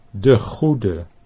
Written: De Goede

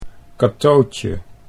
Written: Catootje

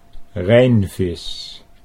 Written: Rhijnvis

